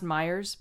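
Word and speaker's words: Meijer: Another one said they found it in a local store called Meyer. I thought that was cute. – Cute. – Yeah. – It's pronounced Myers, but...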